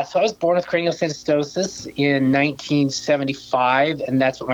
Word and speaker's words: craniosynostosis: You were born with, let me see if I can say this correctly, craniosynostosis? – That's pretty darn close.